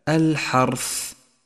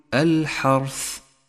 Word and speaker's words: الحرث: تأمل كلمة الحرث، الحاء مرققة والراء مفخمة ولذلك لونت باللون الأزرق إشارة إلى تفخيمها، ولكن احذر من تكريرها عند النطق، أي هكذا الحرث، فهو خطأ شائع صوابه أن تقول الحرث.